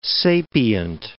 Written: sapient

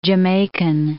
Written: Jamaican

